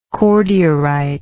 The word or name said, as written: cordierite